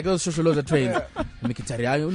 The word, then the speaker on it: Mkhitaryan: This is how you pronounce it, guys. Henrik Mkhitaryan. That's how you pronounce that name.